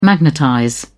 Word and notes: magnetize: British English

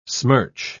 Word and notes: smirch: British English

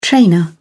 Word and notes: trainer: British English

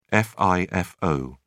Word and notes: fifo: British English

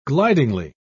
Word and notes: glidingly: British English

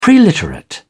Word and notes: preliterate: British English

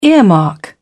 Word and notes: earmark: British English

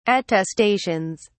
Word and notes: attestations: British English

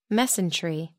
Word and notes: mesentery: American English

